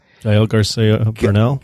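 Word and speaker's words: Gael García Bernal: Gael Garcia Bernal.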